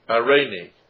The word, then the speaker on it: eirene: Now peace in this verse is a Greek form irene. Irene. And it's spelled E-I-R-E-N-E, probably where we get the common name Irene, which means peace.